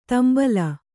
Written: ದುಂಬಾಲ

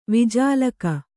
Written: ವಿಜಾಲಕ

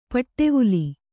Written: ಪಿಡಿವಲೆ

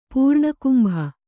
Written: ಪೂರ್ಣಕುಂಭ

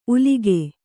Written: ಉಲಕು

